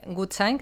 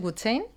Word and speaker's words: Gutschein: Es cierto, a ver, realmente, Gutschein, que es como se pronuncia originalmente, es una palabra alemana que significa bono y le pusimos porque la mayoría de los fundadores hemos vivido en Alemania muchos años. Luego mucha gente que ve la palabra la pronuncia en inglés, Good Chain,